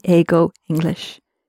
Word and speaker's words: 英語: Tanaka-san wa 英語を話しますから、英語で話してもいいです。And you did correct yourself. The first time, I think you said 映画を話します。And 映画 would be a movie. So just be careful when you're pronouncing 英語 that you distinguish it from 映画. 映画, a movie. 英語, English.